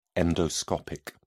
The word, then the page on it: endoscopic: British English